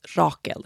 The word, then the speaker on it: Rakel: Rachel in Swedish is Rakel. It's spelled R-A-K-E-L.